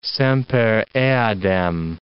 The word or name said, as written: semper eadem